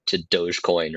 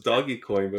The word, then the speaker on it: Dogecoin: Dogecoin or something like that. – That's how you pronounce it? I thought it was doggy coin, but boy, I feel stupid.